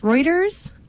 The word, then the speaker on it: Reuters: But, how do you say this? Reuters? – Yeah. – That news outlet, Reuters? Yeah.